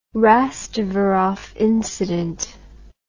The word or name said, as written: Rastvorov incident